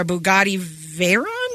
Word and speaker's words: Bugatti Veyron: The most expensive tires in the world are $42,000 per set for a Bugatti Veyron? – Yeah, you're saying it right.